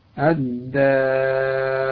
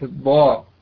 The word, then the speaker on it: الدار: أيضا إذا جاء بعدها حرف الألف البعض يفخمها في مثل الدار وليست الضار، الداع مش الضاع، دائمون دائمون، لابد من النطق بها مرققة، ونرققها ونرقق الألف الذي بعدها.